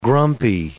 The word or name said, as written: grumpy